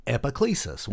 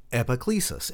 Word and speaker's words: Epiclesis: Say that with me, Epiclesis. One more time, Epiclesis. And it's spelled E-P-I-C-L-E-S-I-S.